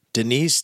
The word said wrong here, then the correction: Dinesh: D'Souza? How do you say his name? – Dinesh.